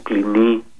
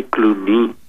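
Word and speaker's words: Cluny: Από τα μοναστήρια και κυρίως από ένα, από ένα φαινόμενο, μπορούμε να το πούμε έτσι, μοναστηριού, που υπήρξε το μεγάλο αβαίο του Κλινί ή Κλουνί, ανάλογα με την προφορά τώρα ο καθένας στα γαλλικά, στη Βουργουνδία, τη σημερινή δηλαδή Γαλλία.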